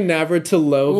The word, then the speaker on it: Navratilova: And Martina Navratilova's taken a go at her as... – Could you pronounce that last name again for us a little slower this time? – Martina Navratilova.